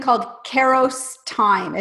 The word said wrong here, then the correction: Kairos: Am I saying that right? I, it's actually I had to look up the pronunciation for that Kairos time. – Kairos, ah, awesome.